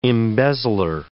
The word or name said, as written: embezzler